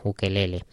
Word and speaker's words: ukelele: tanto en castellano como en inglés, como hemos escuchado aquí, se pronuncia ukelele.